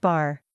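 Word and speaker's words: bar (בר): The Hebrew word for son is pronounced bar.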